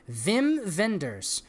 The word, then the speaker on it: Wim Wenders: Wim Wenders.